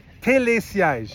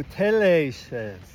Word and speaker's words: Télésiège: First, we gotta learn how to pronounce it. – That's right. Okay. – Télésiège. – Oh, Télésiège.